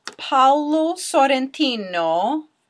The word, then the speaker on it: Paolo Sorrentino: It was directed by a man named Paolo Sorrentino. I totally pronounced that with an Italian accent, just practicing my Italian for my listeners.